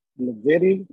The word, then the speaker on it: Blavity: Blavity. – Blavity. – Okay. Blavity. I was thinking. – I was thinking Blavity because I saw Gravity and but instead of GR, I saw BL.